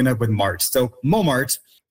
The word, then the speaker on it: Montmartre: And with, you know, same type of process that happened, we have Montmartre, which was the original French pronunciation. And because the sound system of French is so different from the sound system of English, you can see the changes that happen. So we have that nasal on in mon, and we don't have that T. So the T was lost just because it was never pronounced to begin with, but that on became nasal, so we ended up with mo, right?